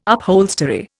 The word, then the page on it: upholstery: Indian